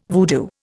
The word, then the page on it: voodoo: Indian